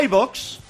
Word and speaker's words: iVoox: Como iVoox y iTunes. ¿Lo he dicho bien? – Sí, perfectamente. Además...